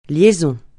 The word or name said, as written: liaison